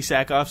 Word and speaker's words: Sackhoff: Am I pronouncing that right? – Yeah, Katie Sackhoff.